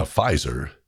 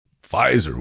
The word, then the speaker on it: Pfizer: Pfizer, ooh, that sounds so techie, doesn't it? That silent P in front of the F?